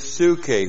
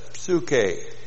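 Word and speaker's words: psuche (ψυχή): For whoever wants to save his, and the word in the Greek is psuche, whoever wants to save his soul, literally what the word here is this, psuche. P-S-U-C-H long E.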